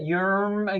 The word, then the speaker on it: Yierm: And just so I'm clear, how do you spell that yerm again? How was that? – Y-I-E-R-M. Yerm. Y-I-E-R-M.